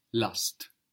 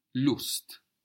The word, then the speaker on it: Lust: And lust is the same word in English and German, lust. So how would you say that? I have lust. – Ich habe Lust. – Good. Ich habe lust, or ich hab lust.